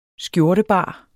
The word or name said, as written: skjortebar